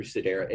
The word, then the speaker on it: Sedera: So if you can figure out ways to reduce that healthcare care bill through the Caris Group, through Sedera, and it's spell it for us, S-E-D-E-R-A.